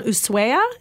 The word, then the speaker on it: Ushuaia: this little town, Ushuaia, is that how you say it? – I believe so.